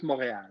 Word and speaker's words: Montréal: Yeah, well, you got to work on the French pronunciation of the team name now. – Okay, I will. – Club de Foot Montréal. So. – Club de Foot Montréal. I understand that R to E-A-L is a little bit difficult for our American palates here.